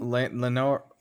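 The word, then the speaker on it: Laenor: Lenor, is that how you pronounce his name? – Lenor, that's right.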